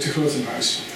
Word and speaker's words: de Grotemuis: Steve introduced me, and he pronounced my last name very well. In Dutch, it is Manfred de Grotemuis.